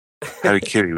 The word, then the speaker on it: harakiri: See, this you pronounce properly, but albino, you got no problem with. It's crazy. Harakiri with the Japanese accent and everything.